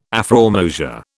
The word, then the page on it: afrormosia: British English